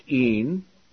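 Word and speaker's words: een (ἦν): The Greek word order of this clause reads, God, theos, a pre-verbal predicate nominative, was, and the Greek verb is pronounced een, the Word, hologos, is a subject, because it has the definite article.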